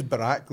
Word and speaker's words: Barack: He says his name is Barack, that rhymes with Iraq, right?